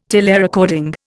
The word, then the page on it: telerecording: Indian